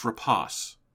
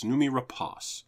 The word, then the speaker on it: Rapace: I always say Numi Rapace. Thank you, Alex. It's Rapace. – Rapace. – I looked up her pronouncing it. It's Noomi Rapace.